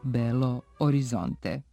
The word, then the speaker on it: Belo Horizonte: Umjesto São Paulo trebalo bi koristiti oblik São Paulo, jer forma sa O ne odgovara ni izgovoru ni pismu, a u Belo Horizonte treba izostaviti H s obzirom na to da je portugalsko H nemo, te će pravilno biti samo Belo Horizonte.